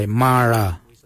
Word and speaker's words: Marra: Number 9, Ma Kuju Kuju is spelled M-A-R-R, then it's G-O-O-D-J-A-L, and then again, G-O-O-D-J-A-L. Ma Kuju Kuju. For the number 10, we say Mara. Mara, which is spelled M-A-R-R-A.